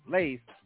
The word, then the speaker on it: Lade: So um, that was Lade. Rhymes with Wade with an L. Lade.